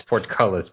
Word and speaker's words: portcullis: Porculus. That's how you pronounce it. – It's portcullis, Brian. It's portcullis.